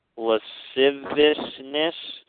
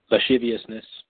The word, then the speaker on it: lasciviousness: turning the grace of our God into lasciviousness. How do you say that word? – Lasciviousness. – Lasciviousness. There you go.